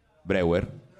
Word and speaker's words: Breuer: C'è Joseph Brauer, penso si, pronunci, si pronunci così. Breuer, Breuer, ecco, vedi, Breuer, vabbè, perdonatemi, è tedesco.